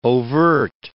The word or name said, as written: overt